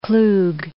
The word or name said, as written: Klug